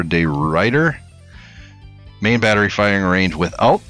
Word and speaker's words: De Ruyter: I think it's pronounced the Ruyter. – Really quick. De Ruyter.